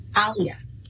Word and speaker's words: Aaliyah: First of all, the name of the protagonist, if you will, the main character, it's spelled A-A-L-I-Y-A-H. How do you pronounce that name? – Alia.